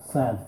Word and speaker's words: sans: And then before I read the last line, I just want to say that he uses a French word, S-A-N-S. In the United States, we pronounce it sans. It's actually pronounced sans.